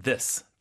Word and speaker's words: this: 하나는 this, 단수는 this, T-H-I-S, 짧게 this. – This. – 근데 여러 개면 복수일 때는 좀 길게 하셔야 돼요. – These.